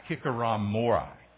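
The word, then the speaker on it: hikikomori: But I don't know if you've heard of this new in its diagnosis, this new phenomenon called hikikorama. No, that's not, I didn't say it right. Hikikoramori.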